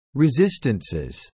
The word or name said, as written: resistances